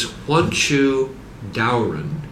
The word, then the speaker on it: Huanchu Daoren: The name that he uses for that author is Huanchu Daoren, right. – which is spelled H-U-A-N-C-H-U, and then D-A-O-R-E-N.